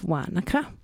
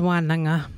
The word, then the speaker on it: Wānaka: It's a season of Wānaka. Yep, that's what that township was named after. Sometimes you'll hear that pronounced as Wānanga.